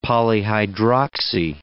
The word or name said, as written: polyhydroxy